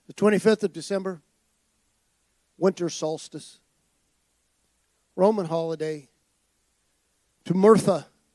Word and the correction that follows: Mithra: or I said that wrong, Mithra, the sun god, 25th of December.